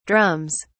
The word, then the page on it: drums: British English